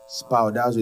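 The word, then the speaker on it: Spoudazo (σπουδάζω): Spoudazo is spelled S-P-O-U-D-A-Z.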